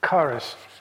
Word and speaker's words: charis: Yeah, well, it's an X. It's K. Yeah, K in the Greek. Charis. We would put a CH with a hard C.